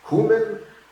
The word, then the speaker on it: cumin: It's actually, the normal spelling is C-U-M-I-N. And then I said, well, I don't even know how to pronounce it. Is it cumin, cumin, cumin? And my dictionary says all three are legitimate. But the first pronunciation is cumin.